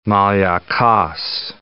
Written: Maliakos, Gulf of

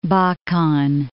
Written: Bakan

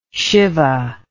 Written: shibah

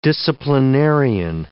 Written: disciplinarian

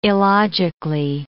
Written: illogically

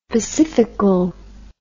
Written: pacifical

